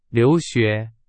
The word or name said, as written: 留学